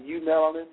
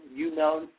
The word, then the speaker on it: eumelanin: We're going to talk about EU melanin, EUMELANIN, EU melanin.